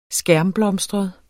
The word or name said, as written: skærmblomstret